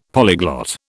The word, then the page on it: polyglot: British English